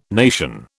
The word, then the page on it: nation: British English